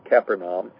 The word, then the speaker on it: Capernaum: And when they had come to Capernaum," and you might, that word, that's the way it's usually pronounced in the United States, but if you go to Israel, it's pronounced Capernaum. So Capernaum or Capernaum.